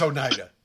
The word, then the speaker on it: Oneida: It is Oneida.